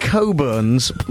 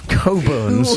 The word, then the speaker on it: Cockburns: Cockburns. Pronounced Coburns. Coburns. – Ooh, Coburns, then. – Port. – I'm just giving you the spelling and the pronunciation in order to make it easier. And yes, it's a port, Coburns. Port, but it's spelt Cockburns, as everybody knows, on the bottle.